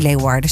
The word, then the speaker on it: Leeuwarden: Februari volgend jaar kun je hem zien in het Fries Museum in Leeuwarden. Zeg ik het goed, hè? – Mooi. Ja, helemaal goed.